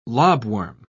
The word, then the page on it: lobworm: British English